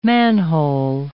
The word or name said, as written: manhole